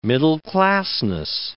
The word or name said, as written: middle-classness